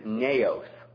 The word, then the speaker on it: naos: Now, the Greek word translated temple here in 1 Corinthians chapter 3 The word translated temple is naos. Naos, and it's spelled N-A-O-S.